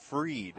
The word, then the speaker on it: Fried: I think it's pronounced Freed.